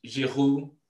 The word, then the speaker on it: Giroud: No offense, Manny, but I watched the highlights and I saw pretty much a boring game until Giroud, I like to say his name the French way, decided to do something spectacular.